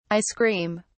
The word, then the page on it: icecream: British English